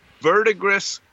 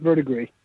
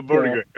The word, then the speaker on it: verdigris: He says, please outline your material and techniques for painting and weathering verdigris copper. Thanks. Did I even say that right? – I think it's verdigris. – Oh, verdigris. Okay. – Close enough.